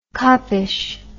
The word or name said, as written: coppish